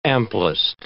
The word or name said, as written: amplest